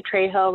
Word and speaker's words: Trejo: So my Facebook page is just my first and last name, Rebecca Trejo. Rebecca spelled with a C, one C, and Trejo is T-R-E-J-O.